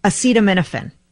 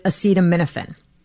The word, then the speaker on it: acetaminophen: The active ingredient in Tylenol, acetaminophen. I've always called it acetaminophen. And I'm looking at the correct pronunciation, all spelled out phonetically, acetaminophen, acetaminophen. So the C and the M are where you accentuate.